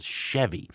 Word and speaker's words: Sheavy: There was a band in the early 90s formed in St. John's called Chevy, pronounced like the car, but spelled S-H-E-A-V-Y, kind of like, is heavy, right?